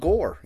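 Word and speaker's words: Gorr: And a guy named Gore, you know? Rhymes with Thor, you know.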